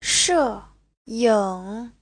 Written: she4ying3

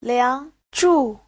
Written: liang2zhu4

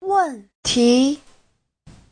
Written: wen4ti2

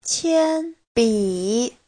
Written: qian1bi3

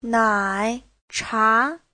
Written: nai3cha2

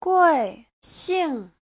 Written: gui4xing4

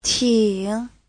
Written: ting3

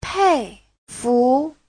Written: pei4fu2

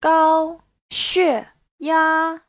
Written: gao1xue4ya1

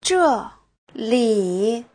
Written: zhe4li3

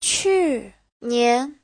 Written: qu4nian2

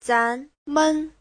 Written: zan2men5

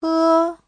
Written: he1

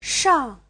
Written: shang4

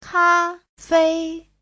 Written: ka1fei1

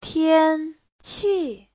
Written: tian1qi4

